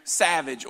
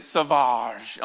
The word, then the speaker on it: Sauvage: Well, I am actually wearing Savage, or some people say Sauvage on the commercial, that's how they say it.